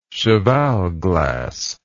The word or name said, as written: cheval glass